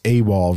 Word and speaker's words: AWAL: It's released on August 16th on, I believe it's pronounced AWAL Records, A-W-A-L.